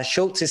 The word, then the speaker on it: Schulze: But just my name, Schultz is spelled S-C-H-U-L-Z-E, but I'm sure it'll be in the show notes.